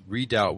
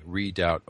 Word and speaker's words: Redoubt: That's Redoubt with a B, silent B. Redoubt, O-U-B-T.